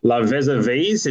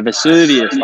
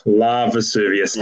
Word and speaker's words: La Vesuvius: La Vesuvies. Is that how we're pronouncing it? – No, Vesuvius, like Mount Vesuvius. – Oh, my God. La Vesuvius.